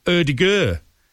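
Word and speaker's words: Ødegaard: Because he's done a video, how to pronounce his own name. And you actually pronounce it Erdegaard.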